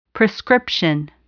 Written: prescription

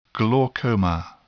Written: glaucoma

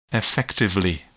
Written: effectively

